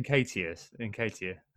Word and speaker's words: Nketiah: Nketiah.